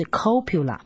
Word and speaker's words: copula: C 这个字是一个拉丁字，这个拉丁字代表是 copula， C-O-P-U-L-A C-O-P-U-L-A。可以发 copula，也可以翻成 copula 啊，也可以把它念成 copula 啊，它的重音不一样而已。